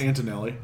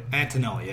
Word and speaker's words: Antonelli: One in particular, Aiden Antonelli, I think is how you say his name. He's got almost 6 sacks, 5.5 sacks. – It's Antonelli. – Antonelli, yeah.